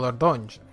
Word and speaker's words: Dordogne: O Dordogne, sería la pronunciación francesa más o menos.